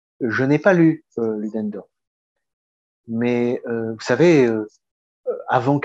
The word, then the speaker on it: Ludendorff: Est-ce la même chose que la guerre totale théorisée par Ludendorff bah, Si je prononce bien. – Oui, oui, Ludendorff, oui, oui.